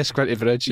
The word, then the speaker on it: Squinty Bridge: Yeah, Squinty Bridge.